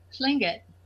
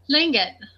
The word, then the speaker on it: Tlingit: Tlingit. – Okay. How do you spell that? – T-L-I-N-G-I-T. – Oh, okay. – See, I thought you said K. There was a K, but no, it's a T.